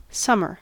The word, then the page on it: summer: American English